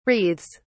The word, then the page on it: wreathes: British English